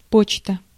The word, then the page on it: почта: /ˈpot͡ɕtə/; potšta